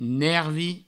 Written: nervi

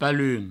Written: palun

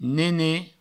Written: nenet

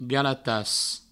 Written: galatas

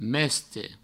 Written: meste

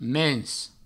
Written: mens

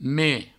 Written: meme

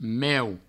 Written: meu

